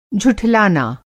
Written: झुठलाना

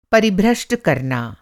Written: परिभ्रष्ट

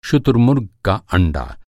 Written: शुतुरमुर्ग

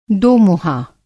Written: दोमुँहा

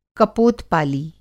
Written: कपोत-पाली

